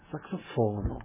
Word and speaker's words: saxofono: Da hören Sie immer auf der vorletzten Silbe bitte betont. Verda Saxofono.